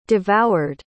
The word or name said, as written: devoured